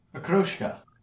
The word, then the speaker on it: okroshka: O-K-R-O-S-H-K-A. – Akroshka.